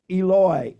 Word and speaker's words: Eloi: And the Aramaic is actually pronounced Eloi, Eloi. The Hebrew is pronounced Eli, Eli.